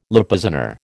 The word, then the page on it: Lipizzaner: American English